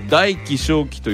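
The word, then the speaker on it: 大機小機: これ大気小気と読むんです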